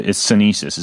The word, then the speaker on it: sinensis: So we've got cordyceps, and it's sinensis is how you pronounce that, right? – Correct. That's right.